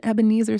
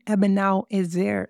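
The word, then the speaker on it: Eben-ezer: Y'all, I've been calling this an Ebenezer stone the whole time. OMG. It is not an Ebenezer stone. It's an Ebenal Ezer stone.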